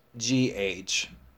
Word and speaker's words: through: The spelling of this word is THROUGH. For this word, the O-U-G-H sound is pronounced OO, through. The OO sound is a long sound.